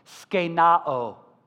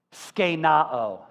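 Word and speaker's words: skenoo: It's spelled this way when you transliterate it into English, S-K-E-N-O-O, S-K-E-N-O-O, but it's pronounced skenao, skenao.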